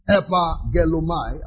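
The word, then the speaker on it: Epagelomai (ἐπαγγέλλομαι): So, now the verb is the word epagelomai. I'll spell it for you. E-P-A-double-G-E-L-L-O-M-A-I. E-P-A-double-G-E-L-L-O-M-A-I. Epagelomai is used as a verb in an utterance, something that was said.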